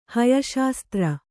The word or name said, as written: ಹಯಶಾಸ್ತ್ರ